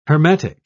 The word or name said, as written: hermetic